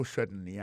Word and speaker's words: certainly: Alors, en parlant de accent, gardez bien pour un mot certainly, accent tombé sur le premier syllabe là.